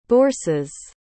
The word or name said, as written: bourses